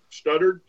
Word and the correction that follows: Sutter: is that how you say his name? – Braxton Sutter. – Sutter, yeah. – Yeah.